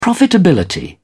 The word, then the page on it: profitability: British English